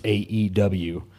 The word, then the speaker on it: AEW: And you don't pronounce it like a word. – I see. – You say the letters AEW, just like you don't say wee when you're talking about WWE.